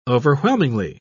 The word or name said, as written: overwhelmingly